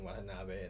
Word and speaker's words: Watanabe: The only other Asian that they use for most things is Ken Watanabe. And they're like, well, we've already used him for Batman. Yeah. So. – Yeah. – Well, I don't know. – That's how you pronounce it. – Watanabe? – Yeah, I didn't.